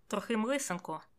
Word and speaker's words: Трохим Лисенко: Але вимовляти насправді легше, ніж дається. Берете і кажете трохим лисенко. І все.